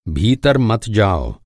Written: मत

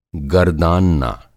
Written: गरदानना

